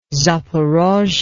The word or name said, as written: zaporozhye